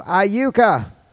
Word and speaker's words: Iuka: Well, it's C-H-A-L-Y-B-E-A-T-E, an Indian name. And a week before that, I spoke at a place called Iuka. Now, it's spelled I-U-K-A.